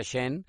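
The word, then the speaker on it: Schoen: Eh, la verdad es que eh, no sé si sepan, pero bueno, el general manager es Shen, que se escribe Shoen, pero se pronuncia Shen, que también llegó de Bills, era el asistente del general manager de Bills.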